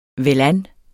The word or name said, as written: velan